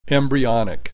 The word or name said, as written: embryonic